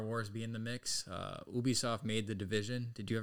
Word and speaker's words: Ubisoft: Nah, but I don't know how to pronounce it. – I've always said Ubisoft. – Ubisoft, that's what I was gonna say too.